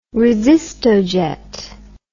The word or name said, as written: resistojet